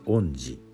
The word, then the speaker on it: 遠志: 遠いという字に志と書いて恩寺と読みます。